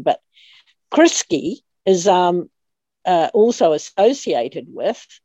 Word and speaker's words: Crisci: But if you know that Jacinda Ardern's other name, or name that she seems to be using in this case, is Criski, C-R-I-S-C-I is how you spell it. – C-R-I-S-C-I. C-I, Criski.